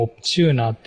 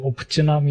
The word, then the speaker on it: Optuna: 1 つ目が、えっ、と、オプチュナの 2.0 がリリースされましたと。はい、はい。今まで自分オプチューナーって読んでたんですけど、オプチュナみたいですね。